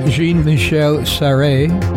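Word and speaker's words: Jean-Michel Jarre: Jean-Michel Charré, I believe that's how it's pronounced.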